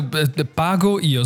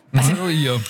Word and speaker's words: Pago io: Ja, du, Pago Io, so wie Pago das Fruchtgetränk. Pago Io.